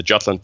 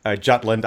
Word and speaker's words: Jutland: You're at this very narrow point in Jutland. I still say Jutland, but that's just German pronunciation.